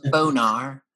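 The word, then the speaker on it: Bohner: I thought that was just like a make Boner. – Pronounced Bonar.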